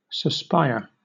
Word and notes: suspire: British English

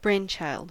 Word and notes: brainchild: American English

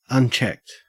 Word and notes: unchecked: Australian English

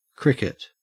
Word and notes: cricket: Australian English

